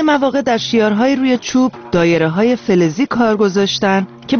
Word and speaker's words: دایره: دال مثل دایره، یکی از سازهای کوبه‌ای اصیل و قدیمی دایره است که در گویش عوامانه داریه هم تلفظ میشه.